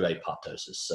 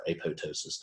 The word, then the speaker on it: apoptosis: It's the true apoptosis, or apoptosis, depending on how you want to pronounce it.